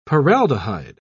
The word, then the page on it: paraldehyde: British English